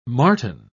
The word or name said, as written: martin